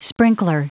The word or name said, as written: sprinkler